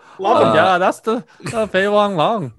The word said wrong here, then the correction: Fei Long: I think you added an L to his middle name, Fei Long.